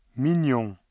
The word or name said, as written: mignon